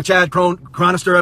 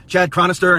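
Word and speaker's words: Chad Chronister: The sheriff, Chad Cronister, I believe is how you pronounce his name, Chad Cronister, and his cohort with the state attorney's office.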